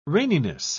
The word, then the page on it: raininess: British English